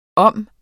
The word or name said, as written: om